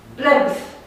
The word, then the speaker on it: plebs: E P toată ziua, da? Nici nu putem să pronunțăm altceva. Plebs. Dar spunem B, plebs. – Plebs. – Plebs. Plebs. Asta este o regulă fonetică. Avea ceva anunțat-o ora trecută. Adică orice labială înainte de S devine P.